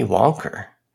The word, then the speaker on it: Wonka: Willy Wonker. Very odd. I like it. Um, that is how people in Boston pronounce Willy Wonka, though.